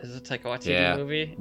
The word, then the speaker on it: Taika Waititi: Taika Waititi? – Taika Waititi.